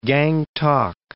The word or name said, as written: Gangtok